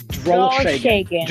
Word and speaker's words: Drolsheng: It is German. It's Drolsheng. Just like it's spelled, pretty much.